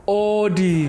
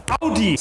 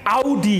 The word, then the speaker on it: Audi: So, the first wrong car name, so many people say Audi. When it's actually pronounced Audi. Say it with me. Audi.